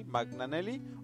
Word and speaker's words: Magnanelli: Bueno, se escribe Magnanelli con doble L al final, Magnanelli. Y tengo entendido que se pronuncia así, Magnanelli o Magnanelli.